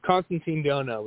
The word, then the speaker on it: Constantine Dhonau: It's Constantine Dhonau, but that's all right.